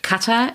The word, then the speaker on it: Katar: Katar, so spricht man es auch aus.